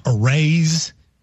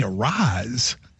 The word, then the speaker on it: Arraez: And if you see Arraez, I would have thought Arraez. And when he told me Arise, I was like, you're screwing me.